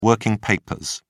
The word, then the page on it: working papers: British English